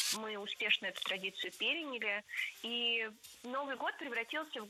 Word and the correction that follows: переняли: Переняли. И это тоже ошибка. Правильный вариант – переняли. Ударение ставим на третий слог.